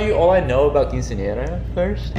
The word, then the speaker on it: quinceañera: How do you pronounce it? Quinceanera? – No. Wrong. It's quince. – Quince. – Nie. – Nie. – Ra. – Ra. Quinceanera. – But a little bit more emphasis on the r.